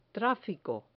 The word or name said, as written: trafico